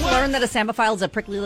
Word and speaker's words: psammophile: But if I were, can you like wink your left eye if I were to try to put like a silent P in front of that? Like P-S-A-M-M-O-P-H-I-L-E? – Let's see. – Samophile. P-S-A-M-M-O-P-H-I-L-E, samophile. – That is correct.